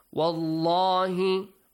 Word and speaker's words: وَاللَّهِ: وَاللَّهِ وَاللَّهِ Allah will be pronounced with a heavy sound.